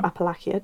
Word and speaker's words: Appalachia: Appalachia. – Mm-hmm. – Or Appalachia, depending how you pronounce it. Apparently it's different on the other side of the mountain.